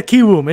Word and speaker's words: Kiwoom: Yeah, KiWOOM.